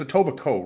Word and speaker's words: Etobicoke: I'm just saying it's Etobicoke, right? Like a silent K.